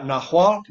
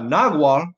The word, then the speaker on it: Nahual: Well, that's the thing is there's like 9 different pronunciations. You got Nahual, which is N-A-H-U-A-L. You got Nahual. You got Nahual. I mean, and all of them are correct.